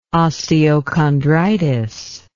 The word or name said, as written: osteochondritis